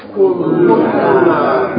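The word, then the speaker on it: Kulluna (كلنا): Kulluna. – Naam. Kulluna. Kulluna. Hold the lamb, right? Because it's two lambs.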